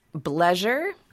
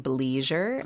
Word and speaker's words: bleisure: And that word is bleisure, or you could pronounce it bleisure, mm-hmm. B-L-E-I-S-U-R-E.